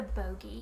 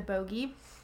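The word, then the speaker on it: Bogge: Because, like, later throughout the books, when she the first time she fights the Boge or the Bog, I don't know how you say it. – It's the Bogey. – The Bogey.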